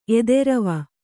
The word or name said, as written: ಎದೆರವ